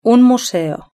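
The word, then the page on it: un museo: European Spanish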